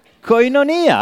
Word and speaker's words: koinonia: In Romania, it's a Greek word. It's koinonia. That's how we put the accent.